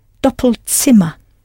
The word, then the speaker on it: Doppelzimmer: You had to change the vowel sound slightly, double to doppel, and I hope you pronounced the Z as though it was a TS, Ts Doppelzimmer.